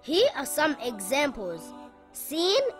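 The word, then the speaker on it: Sin (سين): Sin is a light letter and should not be pronounced heavy. Sin makes a similar sound in English to the letter S in the word sun or the word salad.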